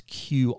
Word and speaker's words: Qi: That's Qi, Qi for short. That's how it's pronounced.